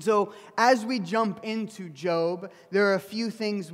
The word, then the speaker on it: Job: If you're new to the Bible, it's spelled like Job, J-O-B, but it's pronounced Job.